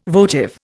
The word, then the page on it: votive: Indian